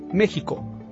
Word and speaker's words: México: De hecho, la Real Academia Española reconoce que la pronunciación correcta en español es con el sonido de la J, México,